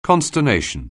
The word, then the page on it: consternation: anglais